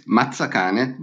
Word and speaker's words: Mazzacane: And am I pronouncing your name correctly? Is it Mazzacane? – Yes, it's Mazzacane, but it sounds well. Okay. – I can say Mazzacane. – Yes, yeah, that's perfect.